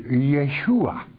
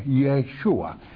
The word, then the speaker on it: Yeshua: And Joshua in the Hebrew tongue is Yeshua, okay, Yeshua, Yeshua. And so that's as it is pronounced.